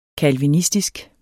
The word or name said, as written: calvinistisk